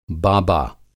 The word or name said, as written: बाबा